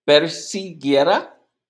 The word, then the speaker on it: persiguiera: La G cuando está con la U no tiene esa pronunciación de gudura. No, es como la G. Entonces es persiguiera. Es gui. Persiguiera.